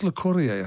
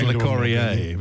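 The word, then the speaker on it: Locorriere: How would you pronounce it? – I'm not... Dennis Le Corrier, I think it is.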